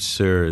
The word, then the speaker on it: Sirr: Here the word sir is spelled S-I-R-R. – That's right. – And that gives it the more correct pronunciation in Farsi.